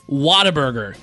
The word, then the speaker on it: Whataburger: We got to try, and I'm saying it correctly now, Whataburger. Am I saying that right, Lenny? – You got it, man.